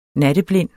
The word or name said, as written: natteblind